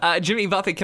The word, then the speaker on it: Jimmy Buffett: So I need to tell you a story about something that happened one time involving the artist Jimmy Buffett. – All right, do tell. – You know our friend Kyle, who used to work with us? – Yes. – He once pronounced it Jimmy Buffet.